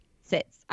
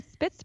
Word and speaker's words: Sitzprobe: Sitzprobe. Sits. I always want to say spits because there's a P in it. you spell it with a P. I want to say spitz probe, you know?